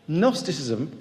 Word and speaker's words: Gnosticism: Gnosticism, you don't pronounce the G, is about knowledge.